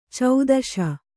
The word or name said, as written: ಛತ್ತೀಸ